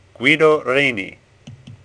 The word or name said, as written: Reni